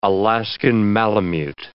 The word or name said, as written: Alaskan malamute